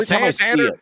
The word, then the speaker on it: Santander: Anthony Santander. Is that how you say his name? Because I always want to say Salamander. – Salamander, Santander, sure.